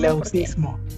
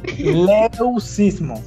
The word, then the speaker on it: leucismo: Leucismo. – Eso mismo, es leucismo. Leucino, bueno, no sé. ¿Cómo se pronuncia? X. No, leucismo.